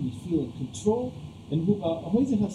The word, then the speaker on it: Huizinga: One of the most important game theorists, a Dutch gentleman who I previously called Johan Huizinga, until a Dutch friend informed me it was pronounced Huizinga,